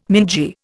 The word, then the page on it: mingy: Indian